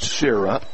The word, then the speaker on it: Cira: Spelled C-I-R-A, pronounced Sierra. – Like the clouds.